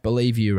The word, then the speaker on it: Bellevue: That last one was pronounced Believe You, right? – Yes.